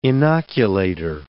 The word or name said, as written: inoculator